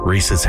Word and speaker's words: Rhys: How do you pronounce R-H-Y-S? – Reese. Oh, Reese. Yeah.